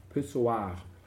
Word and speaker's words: Pissoir: 1000 nackte Weiber auf dem Männerpissoir. – Pissoir. – Ja, ich habe es nur rheinisch ausgesprochen.